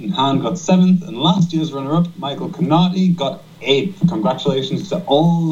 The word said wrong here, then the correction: Connolly: Michael Connolly there, just to pronounce it correctly there.